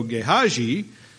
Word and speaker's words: Gehazi: I heard a, a, one of my old preachers preached several messages on Gehazi, and that's how he said it, and it doesn't really matter, but I tried to get the right pronunciation here.